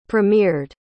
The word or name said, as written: premiered